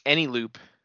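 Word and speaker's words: Eneloop: So I think I'm just going to buy some chargeable batteries and then I've been using Anyloop, E-N-E Loop.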